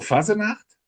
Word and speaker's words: Fasenacht: Und ich war letztes Jahr bei sowas wie Hesse lacht zur Fasenacht. Das richtig ausgesprochen? – Ganz richtig ausgesprochen, wunderbar.